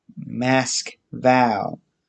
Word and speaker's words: Moscow: Russians themselves call it and pronounce it Mask-Val, with the emphasis on the last syllable, vowel, Mask-Val, because in all but American English, the pronunciation of Moscow is Moscow.